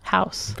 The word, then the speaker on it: Haus: And then I looked over Dory's shoulder, and they, in fact, did call it Dog Hoss. – Yeah, they did. House. – But you said house. – Because that's how you pronounce it in Haus. German.